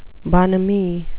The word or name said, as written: bonhomie